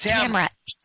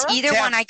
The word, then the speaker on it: Tamara: Yes, it's Tamara. T-A-M-A-R-A. – Tamara? – Yes, Tamara? – Tamara.